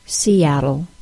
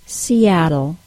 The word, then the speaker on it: Seattle: That stop I added to the word was the glottal stop, and it's the most common problem I hear with non-native speakers when words have adjacent vowel sounds. Specifically, the long E sound links to the short A sound in the word Seattle.